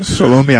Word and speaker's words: Salome: there's a person that said that the mother of James and John was Salome. I guess that's how you pronounce it. – Salome, I would say, yeah.